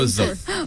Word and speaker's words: Wuzzuf: And that's Wazzaf spelled W-U-Z-Z-U-F. – Yes. A lot of people pronounce it incorrectly. That's the right spelling. – Wazzaf.